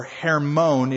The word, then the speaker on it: Hermon: the Hivites below Hermon or Hermon, it would be pronounced in Hebrew, in the land of Mizpah.